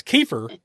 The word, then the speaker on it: Kiefer: I always want to say Keith or Sutherland, but it's Kiefer Sutherland.